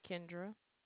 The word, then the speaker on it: Kendra: Kendra. – Can you spell that, please? – K-E-N-D-R-A. – D-R-A, I thought.